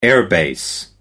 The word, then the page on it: air-base: British English